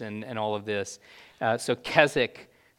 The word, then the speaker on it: Keswick: Keswick. – Keswick. – Yeah, they don't pronounce the W.